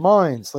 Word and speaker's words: Mines: Mines, like Hines with an M.